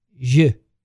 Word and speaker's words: jus: Am I saying this right? Jus. Yeah. dessert is a chocolate souffle with cherry vanilla ice cream, and each dish obviously is paired with a specific wine or champagne.